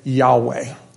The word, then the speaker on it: Yahweh: where more current translations say Yahweh, because it's now believed that that would be a more accurate way of pronouncing those four letters. But whether it be Yahweh, let me just use that for the moment then.